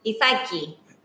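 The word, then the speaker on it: Ithaki (Ιθάκη): Say it in Ithaki. – What she said. That's how they say it.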